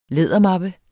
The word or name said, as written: lædermappe